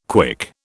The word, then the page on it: KWIC: British English